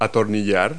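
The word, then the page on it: screw: verb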